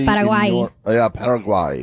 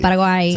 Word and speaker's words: Paraguay: Paraguay. – Paraguay.